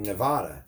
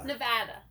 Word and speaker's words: Nevada: In Nevada? – Yeah. – Nevada. – Nevada.